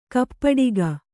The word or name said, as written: ಕೈಪೆಟ್ಟಿಗೆ